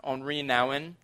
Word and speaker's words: Henri Nouwen: There's a, a writer, a uh, spiritual um, guy does some spiritual direction, uh, a, a man named Henry Nouwen. I, I, think actually his, his name is pronounced Henri Nouwen.